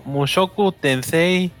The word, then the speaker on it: Mushoku Tensei: A ver si lo digo bien. Mushoku, Mushoku Tensei sí.